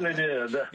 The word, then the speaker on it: Villeneuve: Is that how it's spelled? Pronounced Villeneuve? Must be something. – Villeneuve. – Yeah, that's it. That's better.